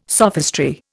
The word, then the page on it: sophistry: Indian